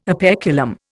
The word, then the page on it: operculum: Indian